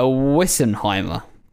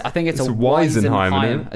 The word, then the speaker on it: Weisenheimer: What is a Wissenheimer? So, I think I'm pronouncing that wrong. I think it's a Weisenheimer.